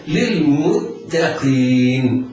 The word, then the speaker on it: للمتقين: للمتقين، التاء جاورت القاف والقاف حكمها التفخيم، فيتحرز القارئ من تفخيم التاء لمجاورتها الحرف المفخم، فلا يسبق لسانه ويفخمها فينطقها قريبة من الطاء أو ينطقها طاء. هذا لحن جلي لا ينبغي للمبتدئ القارئ أن يقع فيه، وإنما يتحرز من التفخيم فينطق بالتاء مرققة، ينطق بعدها بالقاف مفخمة.